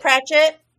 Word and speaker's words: Pratchett: Pratchett.